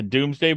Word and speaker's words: Domesday: And they run because Which is kind of funny because there's a book, it's a Doomsday book, but it's spelled Domesday.